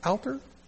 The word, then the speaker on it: Alter: It's A-L-T-E-R. Alter? Alter? How do you pronounce that, Jaggy? Alter, okay.